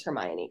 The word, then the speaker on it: Hermione: and mom told me it was Hermione, because it's a British book, right?